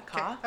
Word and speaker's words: Ka: Ka? Am I saying that right? Do you know? – Okay. Yeah, Ka.